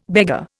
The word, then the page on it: beggar: Indian